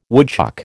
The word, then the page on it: woodchuck: American English